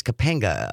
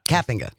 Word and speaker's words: Kapenga: The bill here in Wisconsin, introduced by Representative Clint Moses, a Republican from Menominee, and State Senator Chris Kapenga, a Republican from Delafield. – Kapenga. – Sorry about that. – I've been saying that name wrong for months.